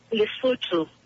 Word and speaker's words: Lesotho: She was born in South Africa and also lived in, I'll probably pronounce this wrong, Lesotho. – And Lesotho. She's been, say that again? – Lesotho. – Lesotho.